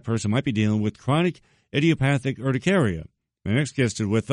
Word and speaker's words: chronic idiopathic urticaria: Two websites to learn more about chronic idiopathic urticaria.